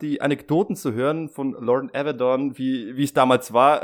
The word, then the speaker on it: Lauren Avedon: White hat er eben auch äh, den Lauren Avedon, so hat er ihn ausgesprochen und so ist es richtig.